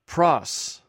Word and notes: pros: greek

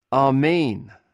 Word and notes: amhn: Greek

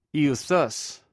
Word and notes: euqus: greek

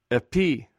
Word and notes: epi: Greek

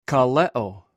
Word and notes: kalew: greek

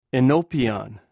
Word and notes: enwpio: greek

